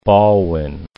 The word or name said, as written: Ballwin